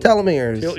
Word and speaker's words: Telomeres: metrines or whatever. What do you call it? – Telomeres. – Your telomeres. – How do you pronounce it? – Telomeres. – Telomeres.